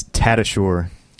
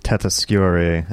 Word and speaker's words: Tatasciore: Hello, it's Tatasciore. – Tatasciore. – Or Tatasciore, I guess, would be the original pronunciation. – And what's the origin of the original pronunciation? – It is Italian.